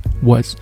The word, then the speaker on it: was: it 那这个位置稍稍有些难，难就难在后面的这个辅音 was 是咱们中国同学比较薄弱的一个发音，也就是这个哇这个声音。